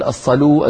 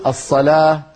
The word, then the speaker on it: الصلاة: الصلاة، ما في عربي يقول الصلو الزكو الحيو، كلنا نقول الصلاة الزكاة الحياة ولكنها تكتب بالواو.